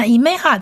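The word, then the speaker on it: imeha (אמה): 那 imeha 这个字呢是三个字母组成的。 imeha 第一个字母是 aleph，一样是末音字母，它不发音，可是它下面的这个一点是母音短母音一。所以它要发 e e 的音。再来，第二个字母是 man，希伯来文字母 man，它发音发 m 的音。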